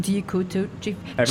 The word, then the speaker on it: Kudachief: More or less, it's phonetically on point. I mean, as far as the spelling, it's you know, Kudachief. Chief is spelled like chief.